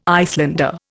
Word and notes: Icelander: Indian

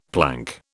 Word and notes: plank: British English

